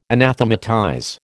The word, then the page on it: anathematize: American English